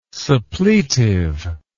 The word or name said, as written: suppletive